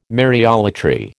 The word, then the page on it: Mariolatry: American English